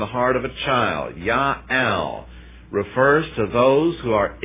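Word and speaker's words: ya'al (יאל): Look in Hebrew, it's yod, aleph, lamed, ya'al. Pronunciation, ya'al.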